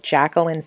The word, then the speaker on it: Jaqueline: My Facebook name is Jacqueline Faith. That's spelled J-A-Q-U-E-L-I-N-E and Faith.